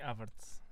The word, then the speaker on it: Havertz: O Havertz. Havertz. – Kai Havertz.